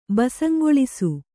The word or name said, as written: ಬಸಂಗೊಳಿಸು